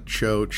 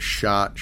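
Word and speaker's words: Chott: It's C-H-O-T-T. – Yeah, it's been pronounced chot, choat, shot, shoat. My dad used to say every time somebody would say shot, he'd say, yep, I'm the big shot. He's the little shot.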